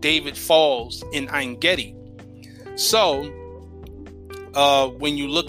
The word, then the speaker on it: Ein Gedi: So the Hermit of Engedi, Engedi is actually, in science, it is actually the, it is a Latin, or I should say an anglicized pronunciation of the Hebrew word Ein Gedi, which is two words.